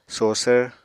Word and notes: sosvr: Apatani

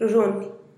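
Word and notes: ruzhunih: Teotitlán del Valle Zapotec

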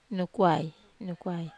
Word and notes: nukwai: Wamesa

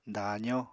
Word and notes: daazó: apatani

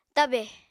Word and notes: tabè: Apatani

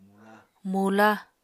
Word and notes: mula: Apatani

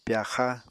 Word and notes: pyaxa: Apatani

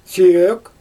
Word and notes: sʲøøk: Xyzyl

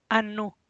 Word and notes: a nu: Teotitlán del Valle Zapotec

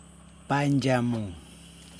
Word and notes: pandJamuN: Yokoim

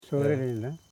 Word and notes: sorenilne: so.re.nil.ne; Koro

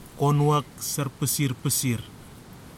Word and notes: konuwak sara phisir phisir: kwamera

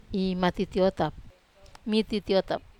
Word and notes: matitiotap: Wamesa

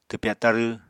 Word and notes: twpya tarw: apatani

